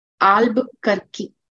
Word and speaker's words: Albuquerque: It's pronounced as Albuquerque.